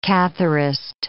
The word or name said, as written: Catharist